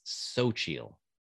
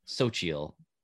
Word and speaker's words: Xochitl: According to Google pronunciation thing, that it's Xochitl. You pronounce her first name Xochitl Gomez.